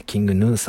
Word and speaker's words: King Gnu: で、さっき調べてようやくキングヌーさんっていうのが判明してですね。キンググヌって書いてキングヌーさんって読むんですね。